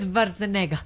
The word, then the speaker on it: Schwarzenegger: Arnold Schwarzenegger.